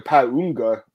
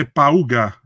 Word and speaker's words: Pauga: They kept calling him Paunga. I don't know where the n in his name came from. I don't know. Like did you hear that? Like the pronunciation. Pa- I think they say Pauga.